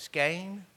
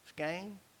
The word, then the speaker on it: skein: But he had a helper with him who had a, let me see if I'm saying that, a skein of thread. Is that right? Where's my sores? Skein? Huh? How you say it? Skein. Skein? Skein, skein, whatever. A ball of thread. And so what he did with his skein of thread, S-K-E-I-N, I know how to spell it, but he took it to the guy and he gave him, he said, here, you take this.